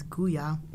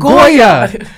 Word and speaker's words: Goya: If you're looking for an internship or a job, I mean, because the black and brown community use Guya. – Okay, Guya. – Guya, yep. – Guya. Just butcher the whole entire company's name. – What is it? – It's Goya. – She really thought it was Guya.